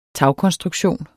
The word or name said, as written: TAGKONSTRUKTION